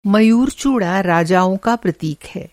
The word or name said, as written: मयूर-चूड़ा